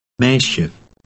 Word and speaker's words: meisje: Look at 14, which ends with SJE. This is pronounced she, resembling the beginning of the English word Shetland. Read 14. – Meisje.